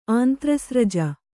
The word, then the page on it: ಆಂತ್ರಸ್ರಜ: kannada